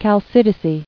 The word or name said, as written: Chalcidian